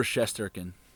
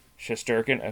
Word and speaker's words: Shesterkin: Yeah, Igor Shesterkin. – Shesterkin, okay. Yep. And then who actually played a little bit last year. And then Alexis Lafreniere, the number one overall pick for the Rangers. Shesterkin is plus 250, and Lafreniere is plus 300.